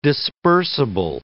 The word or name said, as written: dispersible